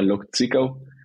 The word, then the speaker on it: Lok Zwickau: Claro, el primer equipo donde vine fue a Lok Zwickau.